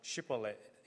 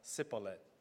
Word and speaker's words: Shibboleth: Because Ephraim, they have the accent, so they were not able to pronounce Shibboleth. Instead, they pronounce Sibboleth.